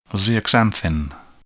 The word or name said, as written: zeaxanthin